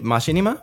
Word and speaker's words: machinima: Machinima? I don't know how I've heard that word pronounced 100 different ways. Machinima. I say machinima because it comes from the word machine, right? – Sure.